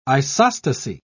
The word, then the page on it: isostasy: British English